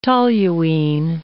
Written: toluene

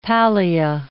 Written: pallia

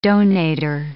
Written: donator

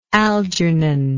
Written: algernon

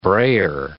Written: brayer

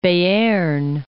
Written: Bearn

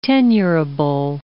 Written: tenurable

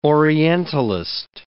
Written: orientalist

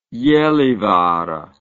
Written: gallivare

